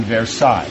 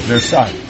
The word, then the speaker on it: Versailles: You know, they were showing the lifestyle in Versailles. Is that how you say it? – Yeah. – Versailles.